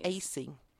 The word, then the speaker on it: Aase: Let me tell you, if you don't mind, about Lee Acey. His name is spelled AASE. First name is Lee, just like it sounds.